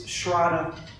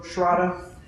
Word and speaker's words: shraddha: So in Japanese and Chinese, the word faith is shin, S-H-I-N. And in Sanskrit, it's shraddha, like rhymes with prada. So shraddha rhymes with prada. In Japanese and Chinese, it's shin, S-H-I-N, to use the Roman alphabet.